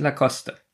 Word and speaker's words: Lacoste: Na, Lacoste. Da ich nicht weiß, wie das ausgesprochen wird. Bin ich im Vorteil. Hast du verloren. Ja. – Das heißt nämlich Lacoste.